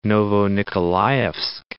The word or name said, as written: Novonikolaevsk